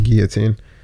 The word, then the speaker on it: guillotine: I don't know why I keep pronouncing that else, because it's just guillotine.